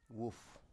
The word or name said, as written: wuf